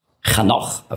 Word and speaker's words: Chanoch: Henoch, Chanoch auf Hebräisch ausgesprochen, heißt der Geweihte, der Hingegebene.